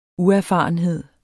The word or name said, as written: uerfarenhed